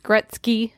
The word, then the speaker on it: Gretzky: Gretzky. Did I say his name right? – That time you did.